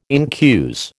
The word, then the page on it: incuse: American English